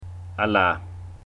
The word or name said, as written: alaa